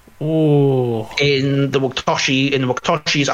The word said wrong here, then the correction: Wachowski: Um, that those directors, um, the uh, Wachowski sisters, um, The Matrix.